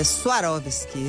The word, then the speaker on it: Swarovski: Ela fala Swarovski, essa é a pronúncia correta aí para quem quiser ser precioso.